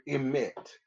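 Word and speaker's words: emet (אמת): Well, the word truth comes from the word emet. – Spell it. – E-M-E-T.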